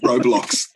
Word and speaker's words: Roblox: Is it Roblox? Is that how you pronounce it? I never know how to pronounce it. – Roblox.